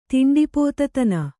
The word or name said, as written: ತಿಂಡಿಪೋತತನ